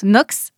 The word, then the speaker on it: Noakes: Charles Knox s'est fait un nom. Ça s'écrit N-O-A-K-E-S. Ça se prononce Knox, c'est anglais, il est né là-bas.